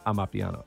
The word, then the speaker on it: Amapiano: I, I pronounced it Mapiano? I meant Amapiano.